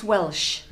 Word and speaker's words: Welch: Well, it's Welsh. It sounds SH, but it's spelt CH.